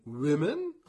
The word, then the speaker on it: women: wird zu einem I, wie in women.